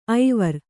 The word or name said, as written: ಐವರ್